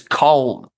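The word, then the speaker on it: Caen: So it's Valenciennes versus Col. So an A and an E should be. It's like A W. – Yeah. – In Col.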